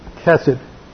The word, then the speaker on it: chesed: Well, first of all, what we have to understand is the word that's translated loving kindness is from the Hebrew word chesed. It's a hard C-H-E-S-E-D, and it means faithful love.